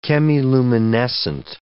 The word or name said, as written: chemiluminescent